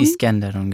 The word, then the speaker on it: Iskenderun: Iskenderun, genau.